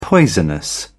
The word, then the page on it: poisonous: British English